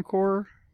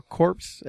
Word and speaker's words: Corps: Hal Jordan and the Green Lantern Corps. Or corpse, as some people say, because they don't know how to say it.